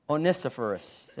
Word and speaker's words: Onesiphorus: In verses 16 through 18, the Lord give mercy unto the house of, how did I say that? Onesiphorus. There we go.